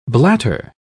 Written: blatter

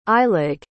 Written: eilig

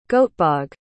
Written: Göteborg

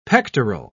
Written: pectoral

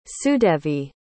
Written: sudevi

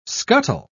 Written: scuttle

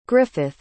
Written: griffith

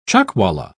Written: chuckwalla

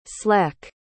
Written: sleck

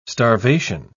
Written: starvation

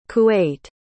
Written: kuwait

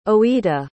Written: oita

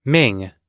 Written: ming

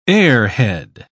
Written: airhead

